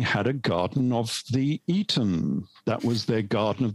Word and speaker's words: Aten: It was called the Aten, or actually we should really pronounce it as being the Eton, a bit like the old public school. It was the Eton or the Eden.